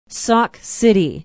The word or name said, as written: Sauk City